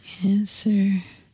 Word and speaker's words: answer: K-N-E-E-L, Neil. – Raspberry, answer. – Silent right. letters. – Yes, silent letters. Right. Raspberry is the silent S. – P. – Well, P. Sorry. – Silent P, right? Or answer. – W.